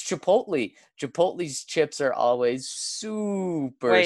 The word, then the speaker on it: Chipotle: And it's pronounced Chipotle.